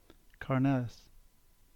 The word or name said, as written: Carnellis